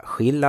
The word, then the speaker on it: Skill: Men heter man Skill så får man ju heta det. Då ska inte vi ändra på uttalet. Så vi ber om ursäkt för det, att vi säkert har sagt fel tidigare. Åsa Skill alltså.